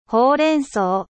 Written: epinard

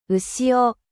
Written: maree

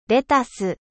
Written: laitue

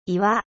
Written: 岩

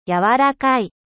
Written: doux

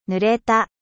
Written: mouille

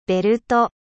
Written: ceinture

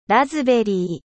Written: framboise

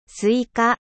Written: すいか

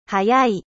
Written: 速い

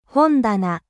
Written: bibliotheuqe

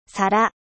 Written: assiete

